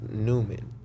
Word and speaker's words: Neumann: I had bought a TLM 103, and it's a Neumann microphone, but it's red, like in English pronounced Neumann, like. Mm. N-E-U-M-A-N-N or some shit like that.